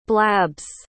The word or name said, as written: blabs